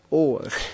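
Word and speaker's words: Ohr (אור): Das ist genauso ausgesprochen wie unser Ohr. – Ja, es wird so ausgesprochen. Langes O. Ohr.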